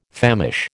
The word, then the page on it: famish: American English